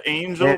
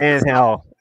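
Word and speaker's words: Ángel: That Angel with Angel. Go Angel. – That soft G, man.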